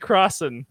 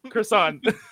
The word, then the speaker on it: Crosson: Oh God, I'm going to pronounce it French now because that's no, all. I'm so self-conscious. I'm Jesse Crosson, Crosson. – Congratulations! – Oh no, you had it right.